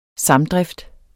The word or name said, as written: samdrift